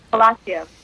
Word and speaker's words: Palacio: It is Spanish, not Italian. – Oh, it's Spanish? – Yes, it is. – So it would not be Palacio. Very good. Palacio, that's how you pronounce it? – Palacio.